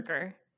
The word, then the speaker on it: Zuercher: Zuercher. Okay. It's spelled deceivingly, but it's pronounced like Z-E-R-K-E-R.